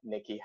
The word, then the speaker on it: Nykky: It's at Nikki Hex, but spelled like N-Y-K-K-Y because I'm a psychopath.